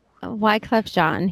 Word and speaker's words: Wyclef Jean: There's a reason why he got the Nobel Peace Prize for his work as a charity, But Wyclef, how do you spell it? Wyclef Jean? Wyclef Jean? – Wyclef Jean. He was a member of the Fugees. – Yeah. I just didn't know how to pronounce his name.